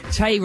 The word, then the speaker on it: Tay: Tay rhymes with hay, which is highly flammable when dry, therefore it's easy to burn.